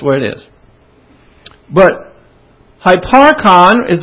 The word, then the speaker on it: hyparchon: The word for existing is not the usual Greek verb to be, but hyparchon. H-Y-P-A-R-C-H-O-N. Of course, in the Greek, that is spelled H-U-P-A-R-C-H-O-N, because there is no Y in Greek, and they always take a hu, hupo, and make it Y, hypo.